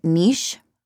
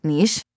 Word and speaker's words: niche: Yeah, I realized I had trouble pronouncing niche, which, thanks to my lovely director, I will now forever see the word quiche when I am trying to read niche.